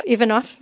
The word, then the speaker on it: Ivanov: Nina Ivanov. Have I got her name right? – Ivanov, my apologies, is based on, again, another character who was a real-life character.